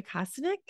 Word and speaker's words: Kostenuk: I am so excited to have Lori Kostenuk.